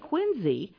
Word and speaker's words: Quincy: Not Quincy, Massachusetts, which is really funny because they pronounce it Quincy.